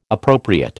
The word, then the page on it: appropriate: American English